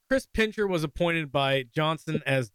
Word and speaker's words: Pincher: Yeah, you're saying Chris Pincher, which I understand. It makes sense because that's how an American would say it. It's Chris Pincher. It's like someone who pinches Chris's.